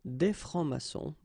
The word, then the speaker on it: francs-maçons: S here and S here. Des francs-maçons. And then you don't pronounce them.